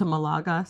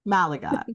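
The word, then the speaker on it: Málaga: She is a full-time creator in the personal finance and travel space that has recently moved from Toronto to Malaga, Spain. Did I say that right? – Malaga. – Okay, yeah. I was so close.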